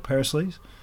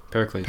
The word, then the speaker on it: Pericles: Perisleys? How do you say the Godolphin horse? – Pericles.